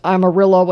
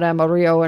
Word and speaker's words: Amarillo: Just like it's Amarillo and not Amarillo and all that, and just like it's San Jacinto and not San Jacinto.